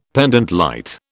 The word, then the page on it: pendente lite: British English